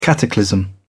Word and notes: cataclysm: British English

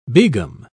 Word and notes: begum: British English

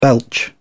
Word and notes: belch: British English

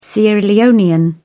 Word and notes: sierra leonean: British English

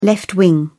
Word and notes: left winger: British English

